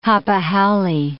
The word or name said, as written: hapa haole